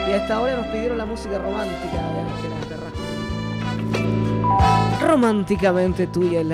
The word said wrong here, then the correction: Ángela Carrasco: la noche, Ángela Carrasco, querer.